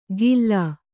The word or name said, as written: ಗಿಲ್ಲಾ